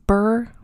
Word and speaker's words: Burgh: The 17 year old Catherine was married to Sir Edward Burr, and that's spelled B U R G H. And I feel like it's pronounced Burr because that's how Lady Catherine de Burgh is said in Pride and Prejudice.